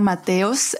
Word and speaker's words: Mateos: Well, yeah, my real name is Elena Mateos, but I go by Elena Matorg in every social media.